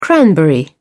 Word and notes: cranberry: British English

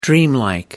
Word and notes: dreamlike: American English

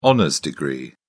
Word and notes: honours degree: British English

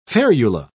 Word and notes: ferula: British English

